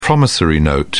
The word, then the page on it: promissory note: British English